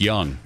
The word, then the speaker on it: Jung: I'm basically so smart that I know that it's pronounced Young.